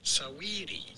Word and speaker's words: Saweetie: It's almost like saying sweetie, but there is a little A sound before. Saweetie.